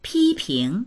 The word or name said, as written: pi1ping2